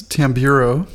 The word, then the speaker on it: Tamburo: I'm going to correct you on my last name. It's Tamburo.